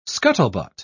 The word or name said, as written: scuttlebutt